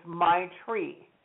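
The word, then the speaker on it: maitri: And so the love is my tree. M is in Michael, A-I, T is in Tom, R-I, and it's pronounced my tree. So you think of a tree, think of your tree, my tree. Okay, the second one is Karuna, K-A-R-U-N as in Nicholas, A, Karuna.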